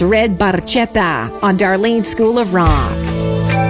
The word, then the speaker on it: barchetta: The correct Italian pronunciation uses a hard K, barchetta, meaning little boat in Italian. However, Geddy Lee famously sang it with a soft ch, barchetta.